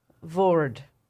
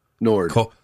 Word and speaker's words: Nord: La Toile du Vord. – Nord.